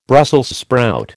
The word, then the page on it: Brussels sprout: American English